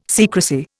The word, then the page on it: secrecy: Indian